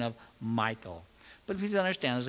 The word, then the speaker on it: Michal: Well, one of them pronounced it Michal, which probably is pretty close to the Hebrew, but I don't know. And the other said the common pronunciation is Michal.